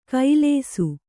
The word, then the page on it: ಕೈಲೇಸು: kannada